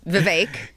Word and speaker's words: Vivek: It's Vivek. – Is it though? – It is Vivek. Is it? – You knew that 12 months ago? – I did not. Yeah. I had no idea. – Yeah. – Until I heard Glenn say it's pronounced like cake.